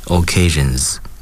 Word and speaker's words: occasions: 记住尾结尾的发音是 z，因为它的这个倒数第二个音节是浊辅音，嗯，所以呢 occasions。